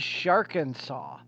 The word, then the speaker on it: Sharkansas: Yes, not Arkansas. I did not mispronounce it. But it is Sharkansas.